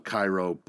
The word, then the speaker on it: Cairo: Now, I know it's spelt Cairo, but I was recently informed that it is pronounced Cairo.